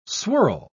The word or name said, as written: swirl